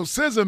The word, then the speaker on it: SZA: Yeah, so SZA, man, I think that's how you pronounce it.